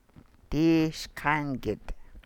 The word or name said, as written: dii sḵaan gid